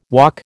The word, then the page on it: walk: American English